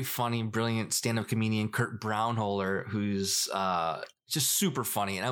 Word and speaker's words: Braunohler: Braunohler.